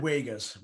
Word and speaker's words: Uyghurs: About their dealings with Hong Kong, not just Hong Kong though, obviously Taiwan, and also the Uyghurs, that's how you say it, isn't it? – Uyghurs. – Yeah, I can never say it properly. I usually just say Uyghurs because that's how it looks like it is spelt.